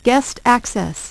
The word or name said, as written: guest access